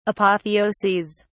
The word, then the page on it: apotheoses: British English